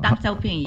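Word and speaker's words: Deng Xiaoping: Dan Xiaoping or? – Dan Xiaoping, yeah.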